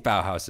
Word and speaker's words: Bauhaus: Am I even pronouncing that band's name correctly? Because I've never heard anyone say it out loud. So. – I think Bauhaus is right.